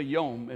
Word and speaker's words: yom: Actually, the word is yom, uh, yom. I, I, I, I, look at it and I want to say yom, but it's actually yom. It rhymes with dome.